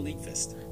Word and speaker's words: Nyqvist: Michael Nyqvist.